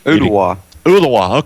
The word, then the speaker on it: Ooltewah: Olathe, I hope I'm pronouncing that right, beat Irwin 48-7. They're out of Tennessee again. – Ooltewah. – Okay, good.